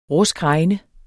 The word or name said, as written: ruskregn